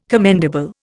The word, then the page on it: commendable: Indian